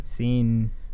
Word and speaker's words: Sean: Who spell their name S-E-A-N. – Seen.